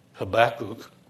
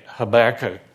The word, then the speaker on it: Habakkuk: In the book of Habakkuk, Pastor Kevin pronounces it Habakkuk, and that's correct, Jewish way to say it. I say Habakkuk because it's a little easier.